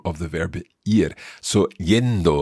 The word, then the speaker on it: yendo: Okay, you'll hear that Anabel is pronouncing that a little like a j sound almost. Yendo, yendo. And that's what we hear when we're looking at double L's and Y's in Spanish.